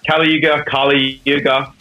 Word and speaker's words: Kaliyuga: First single In Heart's Wake dropped from their new album, Kaliyuga. Am I getting that right, Jake? – Yeah, Kaliyuga. It's up for interpretation how you wish to pronounce it.